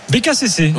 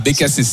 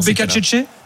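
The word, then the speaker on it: Beccacece: Je voulais justement essayer de te piéger sur la prononciation. – J'ai aucune confiance sur ma prononciation. – C'est un Argentin, donc c'est l'espagnol, c'est ta langue. Voilà. – Bah, Becasese, c'est alors. – Plutôt Becacece ? – Non, ce serait italien.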